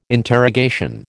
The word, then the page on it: interrogation: American English